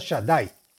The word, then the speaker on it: Shaddai: Y es el santo nombre de Shin Dalet Yud, que se pronuncia Shaddai.